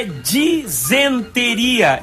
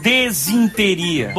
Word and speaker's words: disenteria: A pronúncia certa é disenteria e não desinteria.